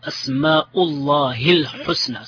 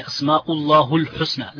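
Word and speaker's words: أسماء الله الحسنى: You know, the proper Arabic way of pronouncing this is Asma'ullahi al-husna. Some people say Asma'ullahu al-husna. It's incorrect.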